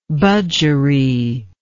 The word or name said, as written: budgeree